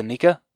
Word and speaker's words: Aneka: Or is it Aneka? – Yep. – It is Anika? – Yep.